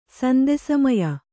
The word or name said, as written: ಸಂದೆಸಮಯ